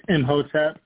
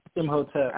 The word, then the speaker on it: Imhotep: It's Imhotep. So it's M as in Michael, H-O-T-E-P. Yeah. – Imhotep. Okay. – The I is silent. Imhotep.